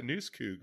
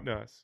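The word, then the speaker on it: newscoug: Remember, that's how it's pronounced, not Nuss. Noose, hanging with the noose.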